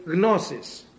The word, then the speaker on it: gnosis: Actually, gnosis. Because there's a G in there, we don't pronounce it in English. In Greek, there are no silent letters. None. Everything is pronounced. Psalma, see? P-S-A-L-M-A.